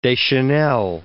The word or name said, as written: Deschanel